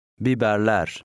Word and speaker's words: Biberler: The E in the ending sounds somewhat close to air. – Biberler.